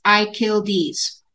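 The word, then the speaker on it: Akyildiz: Yes, his last name is pronounced Achildes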